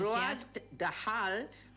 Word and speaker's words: Roald Dahl: Roald Dahl, con obras como Matilda, Brujas, Charlie y La fábrica de chocolate.